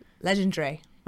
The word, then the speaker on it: Legendre: How do you say that? You know French better than I do. – Legendre. – Okay, so he ran 13:16, which is not far off of the Division One NCAA record.